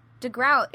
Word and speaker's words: DeGroat: I was definitely just assuming it was DeGroat, and then someone goes, like, DeGroat. It rhymes with boat?